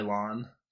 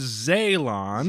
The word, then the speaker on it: Zaylon: Zylon. There is an A in between the Z and the Y. It is Zaylon.